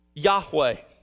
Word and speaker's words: Yahweh: But we believe the best pronunciation is something like Yahweh. Yahweh. It's anglicized. You may have seen it anglicized as Jehovah when you were growing up in older Bibles. But it's Yahweh. Now, in most English translations, it's translated by the word Lord in all caps.